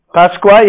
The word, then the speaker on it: Pascua: Pascua, yeah.